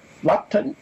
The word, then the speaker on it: Luton: He was the founder of the International Paris Society and a member of the Luton Paris, Luton, L-U-T-O-N, is that how you say it, Steve?